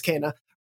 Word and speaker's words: Kena: But yeah, of course, we're going to be talking about, again, to get that vocal SEO, depending on how you're pronouncing it, Kena, Kena, or Kena. I believe it's Kena, Bridge of Spirits, which we'll jump into.